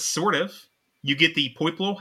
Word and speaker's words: Poipole: Poipole, sorry, said the name wrong. Poipole.